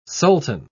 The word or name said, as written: sultan